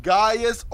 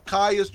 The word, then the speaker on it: Gaius: Gaius or Caius, you'll see it pronounced different ways. Gaius and Caius. Because phonetically, a G and a C can sound alike.